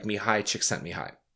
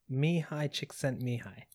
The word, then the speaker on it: Mihaly Csikszentmihalyi: So, okay, so flow was a term that was first coined by a psychologist, a researcher whose name is very difficult to pronounce, but it sounds somewhat like Mihaly Csikszentmihalyi. – Yeah, that sounds good to me.